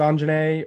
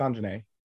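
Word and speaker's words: Aunjanue: and Aunjanae Ellis, King Richard. I apologize. I'm sure I butchered that name. – I think it's Anjanae or on. Yeah, it's Anjanae. – Anjanae.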